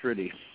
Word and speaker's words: Rude: Just if you want to catch me on Facebook, just look up my name, Thomas J with a period, Rudy, spelled R-U-D-E. It's okay. – Spelled Rude, but it's pronounced Rudy.